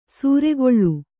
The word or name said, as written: ಸೂರೆಗೊಳ್